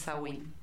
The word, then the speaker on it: Samhain: Era la fiesta, la noche de Samhain, que se escribe Samhain, pero se dice Samhain.